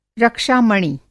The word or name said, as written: रक्षा-मणि